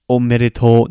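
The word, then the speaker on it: omedetou (おめでとう): So if you're saying the original omedetou, omedetou, no, this is a long O at the end, that's the traditional congratulations.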